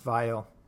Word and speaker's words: Weil: It's spelt Weil, but it's pronounced Vile. – Yes, we'll go Vile.